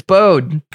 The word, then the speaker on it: Bode: Didn't put an accent on the E. It's Bode.